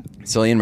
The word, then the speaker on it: Cillian: We both had the same issue, which was that they clearly just got Cillian. Is that how you say his name? – Cillian Murphy, yeah.